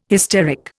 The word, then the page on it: hysteric: Indian